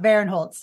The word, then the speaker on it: Barinholtz: Uh, how does how, how, do we pronounce the name? Ike, Ike Bearholtz. – Ike Barinholtz. – Ike, Ike Barinholtz.